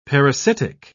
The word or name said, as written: parasitic